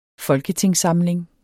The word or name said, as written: FOLKETINGSSAMLINGEN